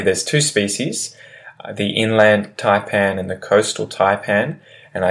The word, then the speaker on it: taipan: A taipan. Taipan. And taipan is spelled T-A-I-P-A-N. Taipan.